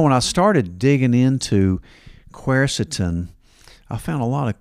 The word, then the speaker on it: quercetin: It's kind of a hard spelling, hard pronunciation too, but quercetin.